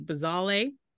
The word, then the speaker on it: Bazawule: It's B-A-Z-A-U-L-E, Bazawule.